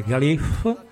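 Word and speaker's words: Gaillimh: Partendo da una città bellissima che è un crocevia tra l'Irlanda rurale e l'Irlanda delle città e che si chiama Galway, nella lingua originale Galif, non vi sto a dire neanche come si scrive.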